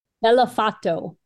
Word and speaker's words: Bellofatto: Bellofatto.